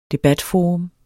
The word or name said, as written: debatforum